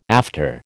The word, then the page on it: after: American English